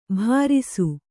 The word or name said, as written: ಭರಿಸು